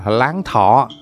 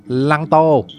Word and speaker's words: Láng Thọ: gọi là láng thọ. À, người Pháp phát âm thành Lăng Tô.